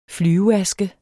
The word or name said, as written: flyveaske